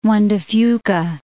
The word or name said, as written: Juan de Fuca, Strait of